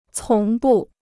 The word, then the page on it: 从不: cóng bù